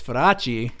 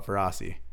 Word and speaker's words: Feraci: Obviously, they had an accent, but I always wondered how to pronounce my last name in Italy, and apparently, it's Feraci. Got to roll that R. – Okay. – But in Americanized, we call it Feraci.